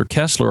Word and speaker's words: Koestler: kind of in that extensive biography about Koestler, he kind of played down the fact that later in his life, Arthur Koestler, or Koestler, as it would have been pronounced in the German, he was actively involved in parapsychological research.